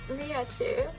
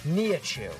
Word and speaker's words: Nihachu: It's Nihachu. – Nihachu.